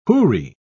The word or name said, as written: houri